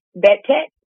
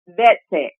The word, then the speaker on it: Vetech: Vetech? – Vetech.